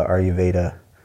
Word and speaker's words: Ayurveda: Definitely want your input on this because I feel like gut health is a big part of the Ayurveda. Did I get that one right? – Yeah, you did say that correctly.